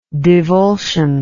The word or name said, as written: divulsive